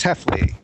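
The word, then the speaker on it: Hefele: H-E-F-E-L-E. I know. – Chris, how do you say your last name? – Hello. It's Chris Heffley.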